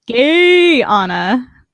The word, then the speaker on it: Guyana: I just wanted to point out that Quest says that it's pronounced Gay-ana. So we're going to go with that pronunciation.